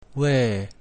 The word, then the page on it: V: Latin